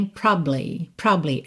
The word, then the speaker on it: probably: Isso só funciona se você estiver falando rápido. Porque se for lento, fica estranho. Probably. Fica na cara que você comeu uma sílaba e que ela tá faltando, né?